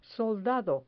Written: soldado

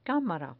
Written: camara

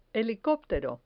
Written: helicoptero

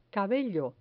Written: cabello

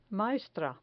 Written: maestra

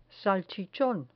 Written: salchichon

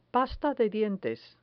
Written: pasta de dientes

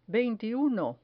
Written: veintiuno

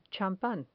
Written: champan

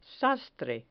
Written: sastre

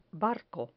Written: barco

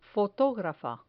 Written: fotografa

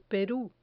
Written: peru

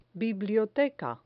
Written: biblioteca

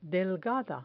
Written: delgada

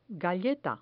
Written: galleta

